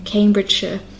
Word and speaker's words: Cambridgeshire: So again, the ones we had here were Hertfordshire, Bedfordshire, and Cambridgeshire. And you can hear that I'm not pronouncing it as shire, but sh. It's spelled S-H-I-R-E, but I pronounce it more like S-H-U-H, like sh. So that's something that you can practice.